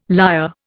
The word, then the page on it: liar: Indian